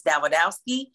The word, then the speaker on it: Dawidowski: Denise Dawidowski. It's actually, it's spelled like or sounds like the widow ski. So if you put the as D A widow ski, mm-hmm.